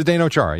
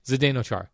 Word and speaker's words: Zdeno Chara: For Washington Capitals star Zdeno Chara. Did I nail that? – Future Hall of Famer. – Did I say his name correctly? – Zdeno Chara, yeah. – Zdeno Chara. – Yes.